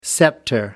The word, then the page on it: scepter: British English